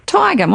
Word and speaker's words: Tagum: Tagum.